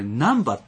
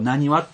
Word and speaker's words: 難波: でこれね、うん、大阪の方は注意してほしいんですけど。うん、これ難波って読まないんですよ。 – あ、ね、この字難しい波と書いて、はいはい、ここ何はって読むんですよ、